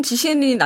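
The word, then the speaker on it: 지셴린 (季羡林): 현대 지셴린은 현대 사람이니까 중국식 발음을 해야 되죠. 한국말 발음을 해도 개선림 아유 더 어렵다 그죠? – 예, 지시엔. 훨씬 지셴린이 낫네요.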